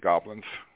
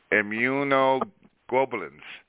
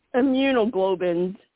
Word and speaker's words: immunoglobulins: But, Doc, can I get the goblins? What? I heard I'm right. – Can I get the goblins? – Immunoglobulins. G-L-O-B-U-L-L-I-N-S. Globulins. Globulins. – Immunoglobulins.